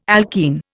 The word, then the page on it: alkene: Indian